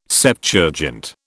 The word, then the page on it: Septuagint: British English